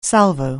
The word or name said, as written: salvo